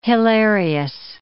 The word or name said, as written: hilarious